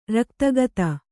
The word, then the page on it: ರಕ್ತಗತ: raktagata